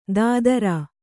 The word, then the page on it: ದಾದರಾ: Kannada